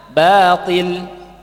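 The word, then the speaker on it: batil (باطل): Sebagian orang karena melihat huruf to, maka menebalkan kalimat ba-nya. Padahal mestinya bacanya batil, batil, ya. Karena melihat to jadi baca batil, batil. Ini salah, ya. Ini kan batil, salah. Yang benar batil, ba, batil.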